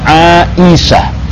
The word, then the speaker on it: Aisyah: A sama I seharusnya dibaca AI. Aisyah. Begitulah dia. Nah, apalagi kalau ini yang pertama huruf ain, yang kedua huruf hamzah. Ya sebenarnya yang keduanya huruf alif, yang ketiganya huruf hamzah. Jadi bacanya Aisyah.